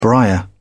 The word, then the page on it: briar: British English